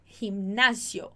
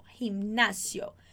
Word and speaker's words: gimnasio: Então, academia a gente chama de gimnasio, gimnasio. Muita atenção, ó. Vou te chamar a atenção. Olha aqui para mim, presta atenção nessa pronúncia. M com N junto.